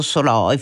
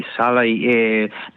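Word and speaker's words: Szalay: Allora, io posso supporre che tanto interesse derive dal fatto che il romanzo di Soloy, naturalmente io lo pronuncio all'ungherese, per me è implicito, però il lettore, e lei anche, è invitato, siete invitati a pronunciarlo alla maniera anglo-americana, insomma, secondo la naturalizzazione appunto dello scrittore.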